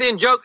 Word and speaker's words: Njoku: I think the N is silent, but it's Njoku, something like that. But I think it's in joke. – There it is. Yes, that's it.